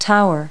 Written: tower

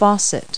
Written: faucet

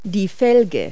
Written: rim

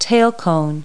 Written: tail cone

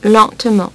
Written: lentement

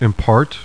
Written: IMPART

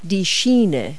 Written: rail

